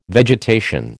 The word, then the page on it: vegetation: American English